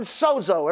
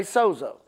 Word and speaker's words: sozo: Well, if you go do a little study on the word saved in this scripture, Romans 10, 9 and 10, it is the word sozo. Everybody say sozo. It's spelled S-O-Z-O with a long line, which means what? A vowel, which you say, you know, O, sozo, which literally means, I wrote the definition down.